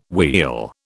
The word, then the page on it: wheel: British English